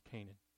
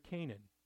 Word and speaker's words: Kenan: I know it says K-E-N-A-N, but it's pronounced Canaan. He fathered Canaan.